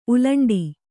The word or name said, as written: ಉಲ್ಲುಂಠೆ